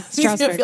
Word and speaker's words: Strasbourg: And am I saying that right? – Yeah, Strasbourg. – Okay, Strasbourg. thank you.